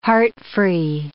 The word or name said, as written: heart-free